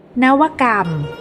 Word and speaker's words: นวกรรม: วัสดีค่ะวันนี้พบกับรายการคำวัดขอเสนอคำว่านวกรรม